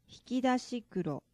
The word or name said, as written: hiki dashi kuro